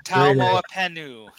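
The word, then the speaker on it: Taumoepenu: Taumoapentu. – Taumoapentu. Is that correct? – No T at the end. Taumoapentu.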